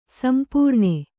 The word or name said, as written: ಸಂಪೂರ್ಣ